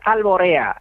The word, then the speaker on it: Alboreá: Es Alboreá. Alboreá, así un pequeño matiz, Alboreá es el palo flamenco que se canta al alba, o sea, hay una relación semántica a lo Alboreá y es normal, o sea, es una confusión normal.